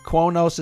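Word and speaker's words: Qo'noS: Quonos is correct, and it's spelled Q-O-apostrophe-N-O-S.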